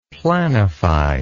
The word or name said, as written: planify